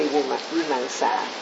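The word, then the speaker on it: vīmaṃsā: That's I mispronounced many times in my talk. I use Vimamsa. It should be Vimamsa.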